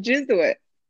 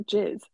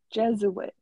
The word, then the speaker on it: Jesuit: Jesuit. – No, not jizz. Jesuit. – Yeah, Jesuit.